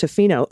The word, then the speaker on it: Tofino: A small town on the west coast of Vancouver Island, pronounced Tofino. Ooh, I got it right.